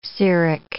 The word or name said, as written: ceric